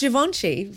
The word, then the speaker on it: Givenchy: Uh, and we've also seen variants of Birkenstocks from labels like Celine and Givenchy. Apologies if I mispronounced any of those names. – It's pretty close, Givenchy.